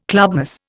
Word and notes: clubmoss: Indian

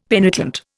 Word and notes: penitent: Indian

